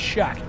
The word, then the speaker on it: Shough: If you listen to this podcast, I'll keep in mind, it's Shuck. I had to educate some other people today. It's Shuck, like S-H-U-C-K. That's the pronunciation. I know it's spelled S-H-O-U-G-H. It is a thing.